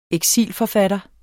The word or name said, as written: eksilforfatter